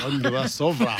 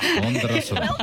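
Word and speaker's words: Vondroušová: She's done an amazing job and she did it really well, didn't she, against, let's get the pronunciation right, Marketa Ondrasova. – Ondrasova. – Ondrasova. Well done.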